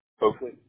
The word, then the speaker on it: Oakley: Oakley, O-A-K-L-E-Y. – O-A-K-L-E-Y, Oakley?